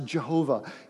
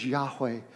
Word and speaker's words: Yahweh: And in older writings, it's usually pronounced Jehovah. In newer writings, Yahweh.